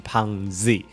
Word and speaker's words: PUNGZ: 那下一位是胖子，他说读音就是胖子没错，他其实是 P U N G Z 哦，就是胖 Z。